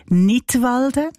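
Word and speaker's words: Knidwalden: Unter einem Titel mit einem Wortspiel Nidwalden, ausgesprochen wie der Kanton Nidwalden, aber geschrieben mit einem K vorne dran. So wie beim englischen Wort für Lismen, to knit.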